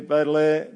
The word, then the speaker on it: Baudelaire: In the words of French poet Charles, how do you say that? Thank you, Baudelaire.